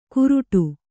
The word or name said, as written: ಕುರುಡು